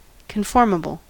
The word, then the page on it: conformable: American English